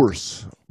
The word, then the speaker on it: Bource: Henry Bourse. – Bourse. – B-O-U-R-C-E. – Oh, rhymes with source, yeah.